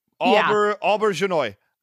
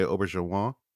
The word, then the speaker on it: Auberjonois: Auberjonois. I think that seems good. – I say Rene Auberjonois.